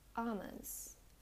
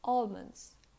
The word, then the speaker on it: almond: Keep in mind that there are two ways to pronounce it. The first is like aw, aw, almond, almond. Or the other way is like almonds, almonds, like all of you. So you can pronounce either almond or almonds.